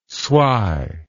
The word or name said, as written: swy